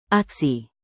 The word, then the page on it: aksi: indonesian